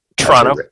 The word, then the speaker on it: Toronto: Toronto. – As a random side. – As Canadians say. – Yeah. – Toronto. They don't pronounce the first O.